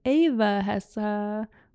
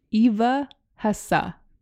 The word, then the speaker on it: Eva Hesse: People always say her name differently. It's like Eva Hessa or Eva Hess or Eva Hess, but it is from her documentary, Eva Hesse.